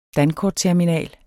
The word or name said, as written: dankortterminal